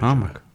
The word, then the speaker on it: Amak: Amak diye mi okunuyor? Nasıl okunuyor? – Amak.